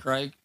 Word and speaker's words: Reich: How do you say that head coach's name? Coach Reich? – Yeah, like Frank Reich.